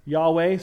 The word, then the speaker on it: Yahweh: Again, as I said several weeks ago, I believe a closer pronunciation is Yahweh.